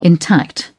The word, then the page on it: intact: British English